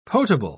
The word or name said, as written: potable